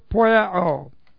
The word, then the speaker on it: poieo: Future, active, indicative of the verb poieo. P-O-I-E, long O.